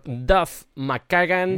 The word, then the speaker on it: McKagan: Vamos con la siguiente canción compuesta por Duff McKagan.